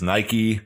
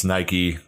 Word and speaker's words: Nike: And so it is Nike. I know that it's not, you know, maybe our dialects are just a little different in the way we pronounce words, but it's Nike, the swoosh, the big check mark.